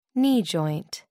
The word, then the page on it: knee joint: American English